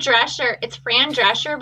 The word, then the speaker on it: Drescher: Fran Drescher. Drescher? I don't know how to say her name. – Well, it's Fran Drescher, but she's the shape of a dresser, like an armoire.